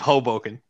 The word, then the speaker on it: Hoboken: She went to, it's Hoboken, right? Am I saying that right? – Hoboken. It's Hoboken.